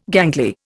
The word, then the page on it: gangly: Indian